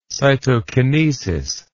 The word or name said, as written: cytokinetic